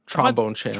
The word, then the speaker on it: Trombone Champ: One last one from Original Cookie Man, who says, Thornbone Champ now being nominated for a dance. Is that, have I said that right? – Dice. Trombone Champ.